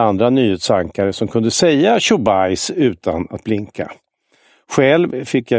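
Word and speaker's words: Chubais (Чубайс): Han heter visserligen Chubais, men det uttalas Chubais och under en för Ryssland viktig period satt han i duman, var vice premiärminister och slogs för att privatisera riket.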